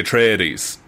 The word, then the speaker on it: Atreides: It's like, Atreides. Is that the pronunciation? – The Atreides, yeah.